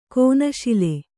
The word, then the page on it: ಕಣ್ಣುಶೂಲೆ: kaṇṇuśule